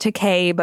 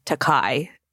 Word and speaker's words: Takei: It is pronounced Takei, but Bill has always said Takai.